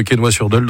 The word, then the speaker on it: Quesnoy-sur-Deûle: Et alors, les habitants de Quesnoy-sur-Deûle dans le Nord, on les surnomme, on les appelle les...